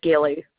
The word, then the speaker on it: Gilley: My last name's Gilly. – Spell it for me. – His last name? G-I-L-L-E-Y is my last name.